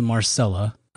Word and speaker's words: Marcella: And we watched a show on Netflix called Marcella, that it's spelled Marcella. And it's just it's right. – Like I was Marcellus Wiley. – Yeah, exactly. That's how I pronounce it.